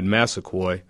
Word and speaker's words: Massaquoi: And after one of the hits, I believe it was the second hit on Mohamed Massaquoi, I believe is the way he pronounces his name,